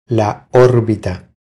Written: La orbita

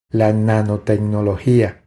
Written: La nanotecnologia

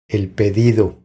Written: El pedido

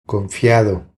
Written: Confiado

